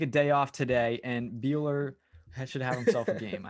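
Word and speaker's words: Buehler: Morton versus Buechler, if I pronounce that correctly. – Walker Buechler.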